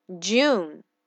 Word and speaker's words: June: Jew, Jew, June. OK，J U N E 啊，June。 OK，June 这个单词是由四个字母组成的，分别是 J U N E，最后的 E 不发音啊，非常简单。